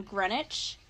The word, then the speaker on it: Greenwich: I honestly am glad I watched a documentary on this case because the town is called Greenwich, but it's spelled Greenwich.